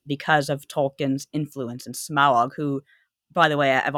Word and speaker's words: Smaug: I always used to say Smog, but no, it's Smaug. Eh, we got to get that kind of second syllable in there somehow.